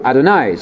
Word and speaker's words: Adonai: Adonai spelled easy because you rhyme with El Shaddai, El Shaddai, da da.